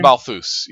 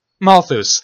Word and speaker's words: Malthus: Malthus. How do you pronounce his name? – Malthus, yeah. – Malthus, thank you.